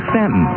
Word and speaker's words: Staunton: And a remarkably short distance up the interstate from the Bassett-Roanoke area is the town that is spelled S-T-A-U-N-T-O-N. And it looks like Staunton, right? Well, you listen. – No thanks, man. I'll see I got better ways. – WKDW-AM Stanton is classic hit country.